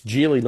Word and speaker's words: Geely: So it's Geely, not Geely, even though it's spelled obviously G-E-E-L-Y, but they pronounce it Geely.